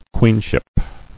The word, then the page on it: Queenship: queen·ship